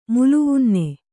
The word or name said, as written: ಮುಲುವುನ್ನೆ